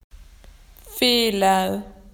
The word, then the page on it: wrong: Australian English